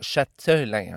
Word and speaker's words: Châtelain: Châtelain, hein, avec un T comme un château, bien sûr. Donc, je me suis aperçu tout à l'heure en réécoutant que on dirait que je prononce Châtelain avec un Q-U-E qui veut absolument rien dire.